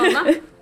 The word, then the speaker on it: Onsala: Tack, och vilket härligt vårväder ni har här i Onsala, så det uttalas. – Ja, precis, ja. Onsala.